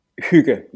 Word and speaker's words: hygge: The second one, it's Hygge, yes. I've heard a lot of different interpretations. I've heard the ones you mentioned there. I've heard Hygge, which I think is also a good one.